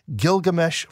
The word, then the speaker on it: Gilgamesh: And as proof, he cites the, am I saying this right, Gilgamesh flood legend. Help us understand what that is and what the argument seems to be. – Yeah, and I think there's actually a simple answer for this man. By the way, you pronounced that correctly.